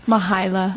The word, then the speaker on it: Mahalia: So pronounce it again. – Mahalia. – That's so beautiful. – So there's no I at the end.